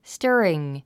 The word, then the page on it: stirring: stə́ːriŋ; スタァリング